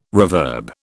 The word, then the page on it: reverb: British English